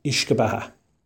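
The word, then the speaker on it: uisce beatha: Well, in Irish, it's pronounced Ishka Baha.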